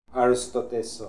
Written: Arostodesokh

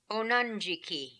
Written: Onandjiki

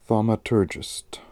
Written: Thaumaturgist

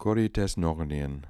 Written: Koritesnokhnian

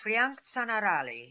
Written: Frianktzanarali

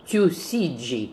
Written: Toosidji

